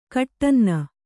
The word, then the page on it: ಕಟ್ಟನ್ನ: Kannada